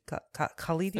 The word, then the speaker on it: Khalidi: By Rashid Khalidi.